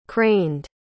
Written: craned

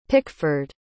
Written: pickford

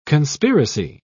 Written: conspiracy

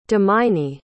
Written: dominy

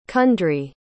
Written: cundry

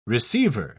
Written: receiver

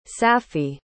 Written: saffi

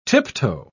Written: tiptoe

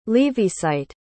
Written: leavisite

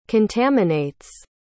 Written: contaminates

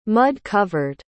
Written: mud-covered

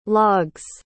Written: log's

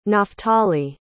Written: naftali

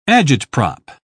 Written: agitprop

